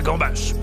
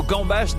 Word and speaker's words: gambas: Sachez en outre que le mot gambas n'existe pas au singulier. Oui, il y a un S final obligatoire qui se prononce comme pour nous rappeler qu'il faut les bouffer à gogo.